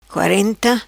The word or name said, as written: Quarenta